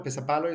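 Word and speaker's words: pesäpallo: Pesapalo is how you pronounce it, right? – Yeah, yeah.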